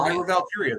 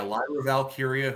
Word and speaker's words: Lyra Valkyria: You got, is it Lyra? Am I saying that right? Lyra Valkyria. – Lyra Valkyria, that's right. Yeah, Lyra Valkyria, who is the women's intercontinental champion.